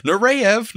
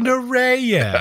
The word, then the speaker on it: Nureyev: Nureyev. – Oh, Nureyev.